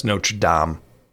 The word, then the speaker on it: Notre Dame: It's pronounced Notre Dame. I don't know why they pronounce it Notre Dame.